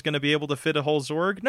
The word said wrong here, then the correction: Zord: Hey, real question: are you pronouncing it Zorg with a G? – Yes. – Why? – How do you pronounce it? – With a D. – Zord.